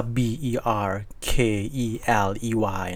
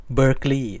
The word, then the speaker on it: Berkeley: สแควร์นะครับชื่อ b e r e l e y Square เนี่ยสะกดว่า B E R K E L E Y นะครับซึ่งชวนให้เราอ่านว่า Berkeley